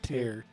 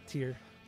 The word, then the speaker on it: Tyr: I think it's pronounced Tear. T-Y-R. – Tear.